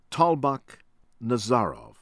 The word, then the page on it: ATOVULLOYEV, DODOJAN: doh-doh- ZHAHN ah-toh-vuh- LAW- yehv